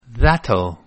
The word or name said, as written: that'll